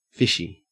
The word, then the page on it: fishy: Australian English